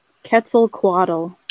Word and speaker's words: Quetzalcoatl: However, you know, we're talking about a time when references to Quetzalcoatl, am I pronouncing that right? In fact, Quetzalcoatl. – Quetzalcoatl. Thank you.